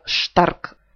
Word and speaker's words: stark: На самом деле пишется stark, а читается stark. Буква сочетания st читается как шт.